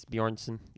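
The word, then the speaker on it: Björnsson: It's Bjornsson. Is that how you pronounce that? I don't know. – It's Bjornsson. Yeah, Bjornsson. Julius Bjornsson.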